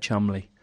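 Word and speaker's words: Cholmondeley: That and the name that's spelt Charmonderly, but you say it Chumley.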